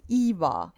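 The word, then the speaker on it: Iva: A is always A, I is always E. That's why my name is pronounced Eva. And there's no way that you're going to meet someone whose name begins with an I that's not pronounced the same way as my name.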